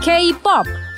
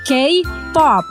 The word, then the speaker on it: K-pop: Oke, ini pengucapan yang benar. K-pop. K-pop. Ingat ya teman Del, K-pop merupakan singkatan dari Korean Pop. Jadi, bacalah K menjadi K sebagaimana pelafalan abjad dalam bahasa Inggris.